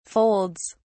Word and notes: folds: British English

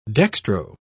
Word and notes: dextro: British English

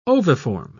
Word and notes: oviform: British English

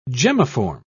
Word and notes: gemmiform: British English

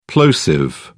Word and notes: plosive: British English

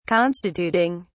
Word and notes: constituting: British English

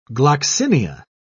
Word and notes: gloxinia: British English

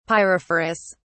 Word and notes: pyrophorus: British English

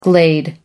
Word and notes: glade: American English

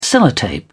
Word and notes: sellotape: British English

